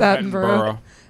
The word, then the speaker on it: Attenborough: Attenborough. – Yeah, it's Attenborough.